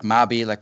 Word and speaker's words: Mabie: And essays like Mabie, I think it's pronounced, it's M-A-B-I-E, kind of proto Emersonian type of, Thoreau type of, transcendentalist, New England transcendentalism type of work, but also has its own character.